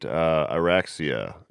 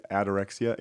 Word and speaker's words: Ataraxia: And I noticed in the sound design, you have one part where she says, to the left, you'll find Araxia. Is that the right pronunciation of that? – Araxia? To the left, you'll find Ataraxia.